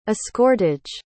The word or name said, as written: escortage